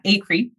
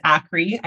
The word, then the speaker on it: Acree: Acree is how you say my last name. Everybody says Acree. I can't do anything about it.